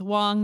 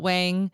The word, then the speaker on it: Wong: Well, yeah, right before we started, I was like, my last name, by the way, is pronounced Wong, not Wang.